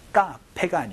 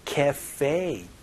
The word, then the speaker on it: cafe: 카페가 아니고 cafe 이렇게 발음하니까 혹은 커피숍이라고 발음해 주시면 좋겠네요.